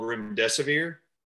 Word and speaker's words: remdesivir: remdesivir, rhymes with run death is near.